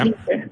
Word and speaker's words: leisure: Leisure? – No, yes, yes, yeah. Leisure. Yeah. And there's two ways of pronouncing it. American tends to be leisure. British and Irish tends to be leisure. Both are right.